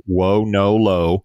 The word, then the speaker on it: Wonolo: So, Chad, before we get to buy or sell, we got another investment, another exciting company, Wonolo. I think I'm pronouncing that correctly. Wonolo. – Yes.